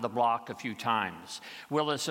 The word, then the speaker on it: Willys: Once I signed the title, I named my Jeep Willis, the original pronunciation of the name of the founder of the Willis Overland Company, which was awarded the contract to produce the first World War II Jeeps. These days, people call Jeeps Willys because of how the name is spelled, W-I-L-L-Y-S, but I prefer the original pronunciation, Willis.